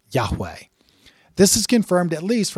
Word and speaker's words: Yahweh: Now, significant Jewish and Christian scholarship generally agree that the sacred name would have been closely pronounced as Yahweh in Moses' day.